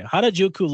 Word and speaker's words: Harajuku: And so they also talked about her first fragrance collection, which and correct me if I'm wrong on how to pronounce this. Is it Harajuku? Okay, Harajuku Lovers,